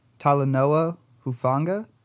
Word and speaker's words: Talanoa Hufanga: And starting off with the guys who did not participate, safety Talanoa Hufanga. Did I say that right? – Yep.